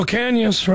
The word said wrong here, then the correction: Ocañas: right there. I'm saying that right. Right, Jude? – Ocañas. – Ah, I'm getting there. Ocañas, got it.